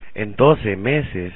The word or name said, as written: en 12 meses